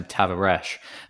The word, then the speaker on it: Tavares: I think you pronounce his surname Tavares.